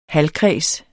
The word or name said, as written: halvkreds